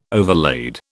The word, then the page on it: overlaid: British English